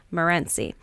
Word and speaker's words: Morenci: We're in Morenci, Michigan, or Morenci, Michigan. I always put the wrong emphasis on the wrong syllable. Morenci.